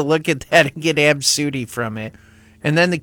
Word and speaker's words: Abcde: The name A B C D E, but that's how it's spelled, but it's pronounced Absudy. I think I said that right. Absudy.